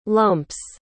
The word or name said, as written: lumps